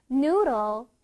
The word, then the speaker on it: noodle: 자, 이거는 어떻게 발음하면 되냐면, NOO를 길게 발음해주고, DLE를 짧게 발음해줘야 돼. 다시 들어봐. Noodle. Noodle. One more time. Noodle. Noodle. 끝에 들 말고, 를 소리를 내줘.